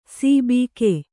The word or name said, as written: ಸಿಬಿಕೆ